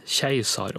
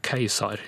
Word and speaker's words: keiser: I norsk ordbok så er det ført opp eh, som normaluttale både keiser og keisar i det ordet her. Norsk riksmålsordbok fører opp keiser som normaluttale og keiser som dialektalt og folkelig.